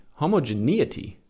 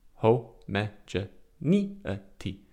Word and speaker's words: homogeneity: click on Merriam-Webster, see if I'm even pronouncing that right. Homogeneity. Homogeneity. Okay. Not neity. Homogeneity.